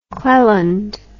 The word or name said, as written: cleland